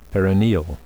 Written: perineal

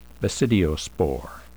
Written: basidiospore

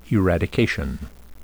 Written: eradication

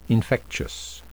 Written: infectious

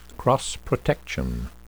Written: cross-protection

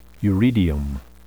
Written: uredium

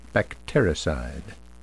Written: bactericide

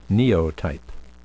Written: neotype

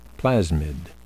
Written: plasmid

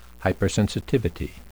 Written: hypersensitivity

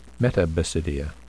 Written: metabasidia

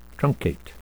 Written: truncate

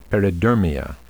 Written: peridermia

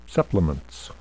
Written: supplements

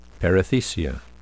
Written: perithecia

